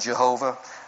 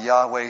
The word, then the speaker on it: Yahweh: Some pronounce it Jehovah. We pronounce it Yahweh here. We just think that's a little closer to what the original most likely was.